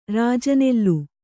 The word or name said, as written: ರಾಜನೆಲ್ಲು